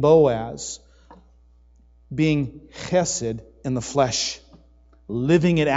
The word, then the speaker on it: Chesed: Chesed. Say it with me. Chesed. Okay, 1, 2, 3. Chesed. All right, now if you spit on your neighbor, you're saying it right, remember?